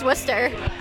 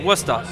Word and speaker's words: Worcester: It's basically, it's like wuss, like you're a wuss, and then a ta at the end of it. Worcester.